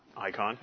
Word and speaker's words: eikon: I- icon. It's spelled in the Greek E I K O N, not I C O N.